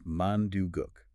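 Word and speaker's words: mandugook (만둣국): The pronunciation is pretty straightforward. It's mandugook.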